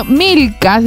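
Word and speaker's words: Milca: Milka me pusieron con K y Rebeca con doble C. Al menos el Rebeca era para atinarlo. Pero Milka se escribe con C y Rebeca solamente con una C, por favor.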